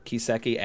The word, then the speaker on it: Kiseki: So K I S E K I. Yes. Pronounced Kiseki.